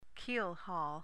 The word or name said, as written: keelhaul